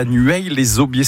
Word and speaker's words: Nueil-les-Aubiers: Philippe, qui habite à Nueil-les-Aubiers. C'est comme ça qu'on le prononce? – Ouais, c'est comme ça.